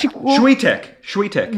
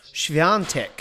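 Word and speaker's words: Świątek: Shwetek. – Well, that's how it looks when it's spelt, but it's actually pronounced Shviantek.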